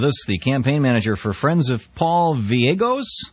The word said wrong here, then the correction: Gallegos: Am I pronouncing that right, Richard? – We say Gallegos. – It's Gallegos. – G-A-L-L-E-G-O-S. – Oh, there you go. I wrote it down, Gallegos.